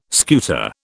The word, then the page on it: scuta: British English